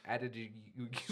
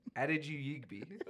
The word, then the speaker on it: Adejuyigbe: Okay, well, it's Adejuyigbe. – Whoa. – Yeah, so it's Adejuyigbe. – Scrabble, high score. – Say it one more time. Say it one more time. One final pronunciation. – Okay, wait, let me think this through. Adejuyigbe.